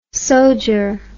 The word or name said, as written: sodger